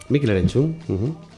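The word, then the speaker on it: Mikel Erentxun: A Mikel Erentzun. ¿Cómo se dice? – Mikel Erentzun, bien.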